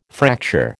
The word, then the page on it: fracture: American English